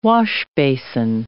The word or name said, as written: washbasin